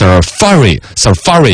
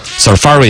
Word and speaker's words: Safari: 他还专门搜了一个这个英语字典那个出来，实际的发音呢是 Safari 应该是这样子读，嘎。 Safari 应该是重音在第二个，好像我之前是有印象的 Safari 浏览器，苹果的浏览器 Safari。